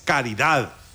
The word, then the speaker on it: caridad: No, es caridad, con D. Una obra de caridad, no de caridad.